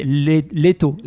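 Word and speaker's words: Leto (Лето): Je vous recommande d'aller sur YouTube et de regarder le clip de Liet qui circule déjà. – Pour le trouver, c'est difficile quand c'est Daniel qui le prononce. C'est Leto.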